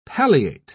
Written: palliate

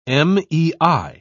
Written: mei

